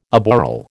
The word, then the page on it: aboral: American English